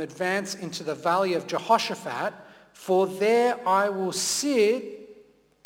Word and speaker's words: Jehoshaphat: I will gather all the nations and bring them down to the valley of Jehoshaphat. Well done for your pronunciation there. It's hard work to say that.